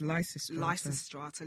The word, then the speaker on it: Lysistrata: So basically, this film is loosely based on the Greek play, I hope I pronounced this correctly, Lysistrata. – I think it's Lysistrata. – Lysistrata.